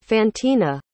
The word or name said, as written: fantina